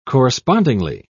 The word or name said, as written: correspondingly